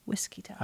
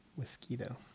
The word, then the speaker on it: Whiskita: And it's spelled with the whiskey and then ta. I guess it's for Wichita, but it's Whiskita. – I was thinking like mosquito. Whiskito. – That's Whiskita. Whiskita.